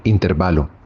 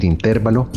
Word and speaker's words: intervalo: Este sustantivo es una palabra grave terminada en vocal. Por lo tanto, se escribe y se dice sin tilde, es decir, intervalo. De ahí que el diccionario panhispánico de dudas considera errónea la pronunciación y la grafía como esdrújula, por lo cual decir o escribir intervalo está mal.